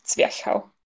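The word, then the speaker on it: Zwerchhau: German speakers usually say Zwerhau for the action. So with the CH at the end of the word, so Zwerhau.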